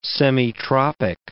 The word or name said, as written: semitropic